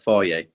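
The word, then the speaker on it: Fage: I call it phage, but I think it's pronounced faha. – Faye, yeah.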